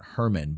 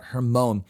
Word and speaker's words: Hermon: Now, my guess would be that the Mount of Transfiguration is Mount Hermon, or Mount, we would say Mount Hermon, but that's really a mispronunciation. Mount Hermon, which is the highest mountain in Israel.